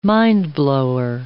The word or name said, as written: mindblower